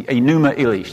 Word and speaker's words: Enuma Elish: I'm writing that up now because it's the Enuma Elish. That's how you say it, pronounce it.